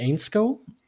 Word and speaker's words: Ainscow: Dann kommt aus Großbritannien, aus Manchester, Max Crazy Ainscow. Ja, auch britischer Receiver. – Klingt richtig ausgesprochen. – Wie bitte? – Es klingt richtig ausgesprochen.